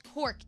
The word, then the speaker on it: Knork: This is called Cork. It's a silverware brand. It's spelled K-N-O-R-K.